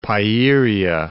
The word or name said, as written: Pieria